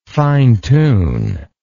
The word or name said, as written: fine tuner